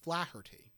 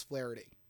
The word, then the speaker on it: Flaherty: And finally, number seven, Deputy Mayor Mike Flaherty, or Flaherty. It's spelled Flaherty. I believe it's Flaherty.